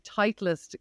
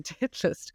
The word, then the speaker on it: Titleist: selling Titleist golf balls, but I pronounced them titlist golf balls.